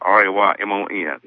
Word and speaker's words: Raymon: My name is spelled Raymon.